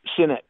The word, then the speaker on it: Sinnett: Sinnett.